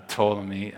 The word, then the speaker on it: Ptolemy: It's hard to pronounce, it starts with PT. How do you pronounce that? Uh, Ptolemy, uh, and the descendants of him uh, who came into power.